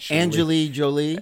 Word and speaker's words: Angelina Jolie: Angelina Jolie.